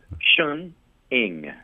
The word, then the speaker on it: Sheng Ing: Am I saying his name correctly, Sheng Ning? – Close. Sheng Ing. – Sheng Ing, yes. – Yeah, as if it were spelled I-N-G.